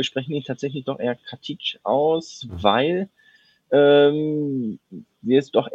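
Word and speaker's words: Katić: Bei mir murmelt es gerade so ein bisschen im Kopf immer, wenn ich mit Nikola Katic, er hat uns doch in der Mixzone gesagt, dass er so ausgesprochen wird. Und jetzt nennen ihn trotzdem alle Katic.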